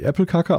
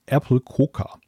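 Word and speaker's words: Äppelkaka: Apple Kaka wird nicht Apple Kaka ausgesprochen, sondern ganz weich Apple Coca.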